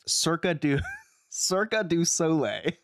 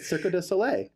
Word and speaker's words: Cirque du Soleil: Alex also said the word circa, but it was in the context Cirque du Soleil. – That's how you say it. Cirque du Soleil. – No, it's Cirque du Soleil.